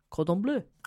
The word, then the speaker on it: Cordon Bleu: Cordon Bleu.